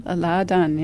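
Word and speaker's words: Láadan: How do you say it? Ladan? – Ladan, yes.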